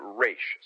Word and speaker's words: Ra's: Both Julie and Denny pronounced it Raish, so Raishagul out of Denny O'Neil's mouth.